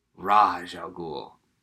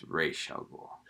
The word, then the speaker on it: Ra's al Ghul: Sometimes it's like Ra's al Ghul. But if memory serves, the guys who created the character say it's Ra's al Ghul. – I think the two creators pronounce it the two different ways.